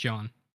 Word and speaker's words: Jahn: So if your dad's John, you go with John, but it's J-A-H-N. – J-A-H-N? Jan. – Jan. – Hi, my name's Jan. – My name's John. It's pronounced John. – Right. It's written phonetically how you would say John if you were from Boston, but it's still John.